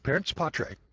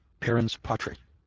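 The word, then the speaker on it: parens patriae: Parents patre, parents, P-A-R-E-N-S. And that's spelled P-A-T-R-I-A-E, parents patre, P-A-T-R-I-A-E.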